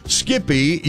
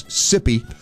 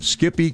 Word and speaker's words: Skippy: I did not know what Skippy was all about, so I, of course, mispronounce it as Sippy.